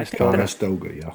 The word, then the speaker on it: Conestoga: Might be Conestoga. – Yeah. – Conestoga.